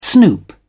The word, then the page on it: snoop: British English